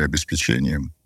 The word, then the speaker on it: обеспечение: И это прямая аналогия с программным обеспечением или обеспечением. – Программное обеспечение? А обеспечение это что-то? – Обеспечение — это неправильное ударение. – Да, да. Не, оно правильное, но в другом контексте.